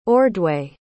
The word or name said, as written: ordway